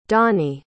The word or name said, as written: doney